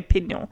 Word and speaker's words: Pignole: Signor Giuseppe Pignole. So the G is silent.